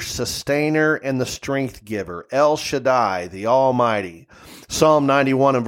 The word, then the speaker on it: El Shaddai: Now, the word Almighty in Hebrew is pronounced El Shaddai, El Shaddai.